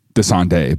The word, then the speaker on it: Dasani: Well, it's actually Dasande, but go ahead. That's how they say it, you know? – If you watch their commercials. – If you watch their commercial, it's like Dasande, like...